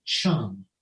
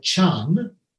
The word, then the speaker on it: Chan: And the Chinese word chan is basically the way the Chinese pronounce the Indian word jhana.